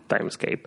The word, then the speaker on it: Timescape: No sé cómo se lee. Timescape, ¿no? – Timescape. – Time SKP se escribe.